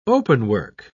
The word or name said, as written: openwork